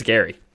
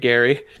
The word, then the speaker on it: Geri: So Freki is spelled F-R-E-K-I, could easily be Freki, and Gary is spelled G-E-R-I. – Oh, yeah. – So make that what you will, but I think it's Gary.